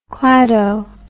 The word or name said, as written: clado